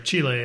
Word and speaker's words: Chile: So these were made in Chile, or Chile is how you say it in Spanish.